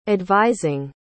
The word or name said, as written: advising